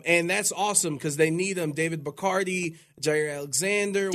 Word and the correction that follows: Bakhtiari: I thought he chugs beers, no, no, not, not Bacardi's. Bacardi. Uh, Bak- Bakhtiari. – Bakhtiari.